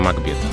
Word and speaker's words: Макбет: А если мы будем читать Шекспира, то надо замахиваться на Макбет Так. А дело в том, что по правилам произношения в английском языке а, надо говорить Макбет, приставка Мак никогда не бывает ударной.